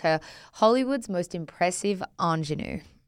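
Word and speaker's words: ingénue: Good pronunciation of that word. – I looked at it and I thought, is it going to come out right the first time? – Ingenue. I think you were bang on.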